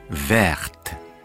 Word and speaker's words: verte: Но в женском роде, если мы говорим «зеленая куртка», la veste – verte, la robe – verte – «т» читается на конце.